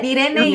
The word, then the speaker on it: Irene: What I love right now is that you're both saying my name in Spanish. I love it. Irene, yes, Irene.